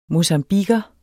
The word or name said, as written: mocambiquer